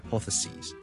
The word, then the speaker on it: hypotheses: So hypothesis, hypothesis plural 我哋係 s i s 轉咗做 s e s，就變咗 hypotheses, hypotheses, hypotheses。記住個發音，OK？由 sis 轉咗做 sees，你當好似係 c s e a 或 s e e 嗰個 c，跟住再加多 s。